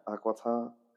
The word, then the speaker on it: Aquitaine: How do you pronounce it? – Aquitaine.